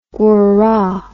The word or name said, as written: guara